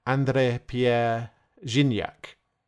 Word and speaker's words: André-Pierre Gignac: Ah, yes, that's André-Pierre Gignac. – Not sure that's how you say it. Good try, though. I think it's more like André-Pierre Gignac.